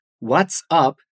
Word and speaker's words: What's up: Checa cómo, aunque son dos palabras, What's up se pronuncia como si fuera una sola.